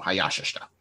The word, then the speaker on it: Hayashishita: Yeah, with the the ya is the stress syllable. Hayashishita.